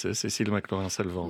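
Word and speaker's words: Cécile McLaurin Salvant: Cecile McLaurin, Salvant, Sherman Irby, arrangement and alto saxophone.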